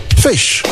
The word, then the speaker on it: ghoti: Goatee, G-H-O-T-I. It's pronounced fish.